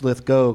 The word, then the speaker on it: Lithgow: Because it's an easy way to remember how to pronounce it. Instead of saying Lithgow, you know it's Lithgow because he's Go-Go Lithgow. – Go-Go Lithgow. Mm-hmm. Got it. – He's not Gow-Gow Lithgow.